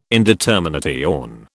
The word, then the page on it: indetermination: British English